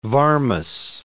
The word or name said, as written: Varmus